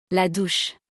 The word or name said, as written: La douche